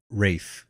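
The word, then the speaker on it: Ralph: Rafe. Right. Is how you say that.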